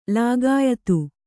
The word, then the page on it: ಲೋಕಾಯತ: kannada